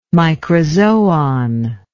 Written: microzoon

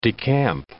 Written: decamp

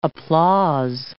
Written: applause

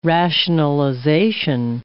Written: rationalization

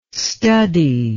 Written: studdie

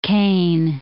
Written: Kane Basin

